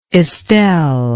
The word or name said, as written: estelle